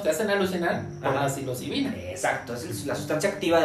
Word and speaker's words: psilocibina: Ahora sí lo dije bien, psilocibina.